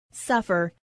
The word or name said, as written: suffer